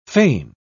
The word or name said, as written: foehn